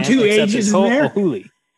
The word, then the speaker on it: Ho'ohuli: It's H-O apostrophe O-H-U-L-I. – Ho'ohuli. – Wyndon Ho'ohuli.